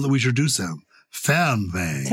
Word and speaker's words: Fernweh: And it's Fernweh. Fernweh. So poetic in German. It's spelled Fernweh. And it means the longing to be far away.